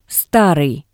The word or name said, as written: Starii